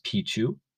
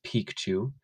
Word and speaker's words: Picchu: Isn't it just Picchu? Well, in Quechua, spoken by the Inca, it's actually pronounced Picchu because of the double C, P-I-C-C-H-U.